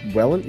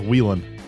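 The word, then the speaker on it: Whelen: Wait, is it pronounced ARCA Wellen? – Wellen? Yeah, it's Wellen. – I've been pronouncing it wrong my entire life. I thought it was Wheelan.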